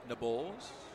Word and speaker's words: Nabholz: N-A-B-H-O-L-Z. That's how Nate spells his last name, pronounced by the PA announcer as Nabols.